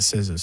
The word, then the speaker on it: SZA: Is this how you pronounce it? Za? I'm doing research. – SZA. – Hey, SZA. All right.